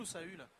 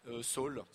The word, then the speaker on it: Saul: Ça se prononce Saul ou Saül ? – Saul. – Saul. – Mais ouais. On pourrait dire Saül, mais il faudrait qu'il y ait un tréma sur le U.